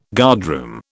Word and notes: guardroom: British English

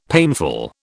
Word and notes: painful: British English

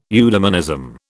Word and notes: eudemonism: British English

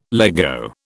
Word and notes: Lego: British English

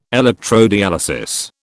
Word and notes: electrodialysis: British English

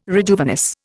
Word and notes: rejuvenesce: Indian

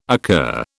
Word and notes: occur: British English

